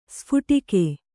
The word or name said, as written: ಸ್ಫೋಟಕ